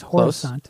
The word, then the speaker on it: Horisont: But yeah, the band is, and God help me if I'm pronouncing this wrong, Horisont. – Horisont? – Yes, Horisont. Close.